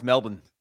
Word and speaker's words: Melbourne: What's funny is that you have Brisbane, which is spelled with a B-A-N-E, and then you have Melbourne and it's still pronounced the same. Brisbane and Melbourne.